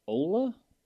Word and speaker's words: Ola: I believe his high school is pronounced Ola. Okay, it's O L A.